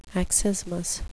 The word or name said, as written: accismus